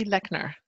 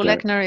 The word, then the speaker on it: Lechner: Yes, Sophie Lechner. So Lechner is of Hungarian origin on my husband's side.